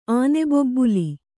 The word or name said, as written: ಆನೆಬೊಬ್ಬುಲಿ